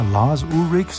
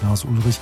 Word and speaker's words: Lars Ulrich: Und da wissen wir im Moment, da gibt es ja Lars Ulrich, so wird er ja ausgesprochen, nicht Lars Ulrich, den Drummer von Metallica.